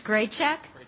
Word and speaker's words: Grajic: The last name is spelled is pronounced Grajic, but you didn't butcher it, and it's epidemiology, for those of you who care about that.